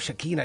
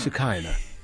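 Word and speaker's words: Shekhinah: Because every time I hear people on the radio go Shekinah, I go no. It's Shekinah.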